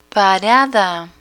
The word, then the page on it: parada: Spanish